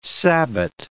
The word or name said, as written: sabbat